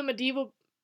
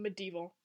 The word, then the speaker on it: medieval: You're putting really hard emphasis? – On the wrong syllable? – Yeah, on, like, the D. You're going... Because it's medieval. – I'm going medieval. – Yes, you are. It's medieval. – It's medieval. Okay.